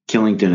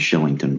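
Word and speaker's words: Kylington: Killington is actually pronounced Shillington, but that's all right.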